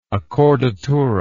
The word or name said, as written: accordatura